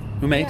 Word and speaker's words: Ume: Ume. Did I say it right that time? – Yeah.